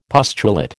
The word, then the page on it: pustulate: American English